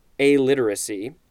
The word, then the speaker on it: alliteracy: So this is alliteracy. I had said it wrong before. It is literally pronounced alliteracy, not illiteracy, which I believe starts with an I.